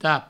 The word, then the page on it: tap: provençal